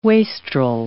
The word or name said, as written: wastrel